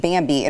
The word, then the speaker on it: Bambee: That is why you need Bambi. It is spelled B-A-M-B-E-E.